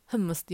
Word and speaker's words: フムス: フムスって読みます